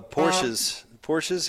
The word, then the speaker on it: Porsche: So Porsches have loud horns? – It's pronounced Porsche.